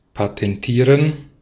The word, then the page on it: patentieren: /patɛnˈtiːʁen/; [pʰatʰɛnˈtʰiːɐ̯n]